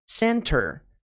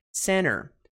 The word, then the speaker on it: center: Eles escolhem simplesmente não pronunciar o T. Não pronunciam o T. Então, assim, a pronúncia dessa palavra aqui, center, center, center, sem o T, imagina que você não fala o T. Tá? A escrita é com o T. Agora, eles simplesmente não pronunciam. Como que ficaria? Center sem o T. Vamos lá. Center sem o T. Center, center, center, center. Isso mesmo. Center é pronunciado por americanos muitas vezes.